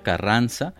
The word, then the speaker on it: CRNZ: Eh, en fin, vamos a escuchar esto que se llama Capricornio del artista Carranza, eh, que se escribe en realidad CRNZ. Eh, CRNZ, eh, algo así como Longshot, pero bueno, en este caso es Carranza, un MC que proviene de Saltillo, Coahuila.